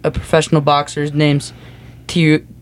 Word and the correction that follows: Teofimo: Teofimo Lopez. Okay, I'm pretty sure you butchered that name, but that yeah, th- that's exactly what I thought.